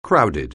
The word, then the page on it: crowded: anglais